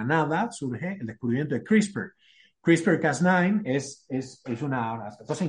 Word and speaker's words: CRISPR: Lo pueden buscar como CRISPR. Esto es una cosa muy, muy, muy importante y reciente, se pronuncia así, es, um, son unos clusters, es, em, de, unas, secuencias de ADN que se llaman ah, palíndromos.